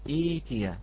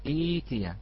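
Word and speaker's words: ائتيا: نعم هذه ننطقها أخي الكريم إيتيا، نعم ننطقها إيتيا ببدال الهمزة الساكنة.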